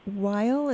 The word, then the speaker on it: Weil: When you talk about Simone Weil, is that how you pronounce the last name? – Yes.